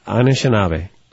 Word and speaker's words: Anishinaabe: Anishinaabe.